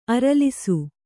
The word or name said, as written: ಅರಲಿಸು